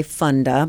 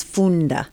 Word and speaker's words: Funda: Well, you know, it looks like fun, so people want to say Funda, but the actual pronunciation is Funda. – Funda. – Funda, which is a Zulu word from the Zulu people in Southern Africa, South Africa, and it means to learn.